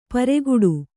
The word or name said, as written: ಪೆರ್ಗಟ್ಟ